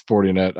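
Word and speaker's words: Fortinet: I'm going to go with 40net. – Yeah, it's 40net. I actually use their software. – Okay. 40net championship.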